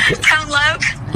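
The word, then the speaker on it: Tone Lōc: Tone Lock. Are you going to Tone Lock? – Tone Loke. – Loke. Oh, we don't even know how to say it. Is that how you say it?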